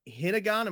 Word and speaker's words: Hinagon: Hinnegan, am I pronouncing that correctly? – That's correct. That's correct.